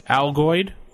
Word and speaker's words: Algoid: We got the Algaloid. That's how I pronounce it. Am I pronouncing that right? – No. This is how many L's did you just put in it? – Algoid. – There you go. – Algoid. – Like Altoids.